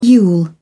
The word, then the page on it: yule: British English